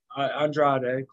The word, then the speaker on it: Andrade: He will be teaming up with his son-in-law on Andrade. Did I say it right? – Andrade, close enough.